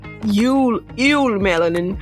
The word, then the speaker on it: eumelanin: P-H-E-O. – Wait. So we got melanin, eumelanin. How did I say that? – Eumelanin.